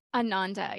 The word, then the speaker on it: Ananda: Today I'm so excited to really dive into your book and to dive into Ananda Elixirs. Am I saying that right? – Ananda, yeah.